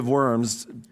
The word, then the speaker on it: Worms: It actually looks like Diet of Worms, but it's pronounced Worms.